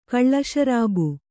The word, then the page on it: ಕಳ್ಳಶರಾಬು: Kannada